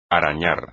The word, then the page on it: arañar: espagnol